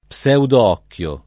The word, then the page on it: pseudoocchio: lingua italiana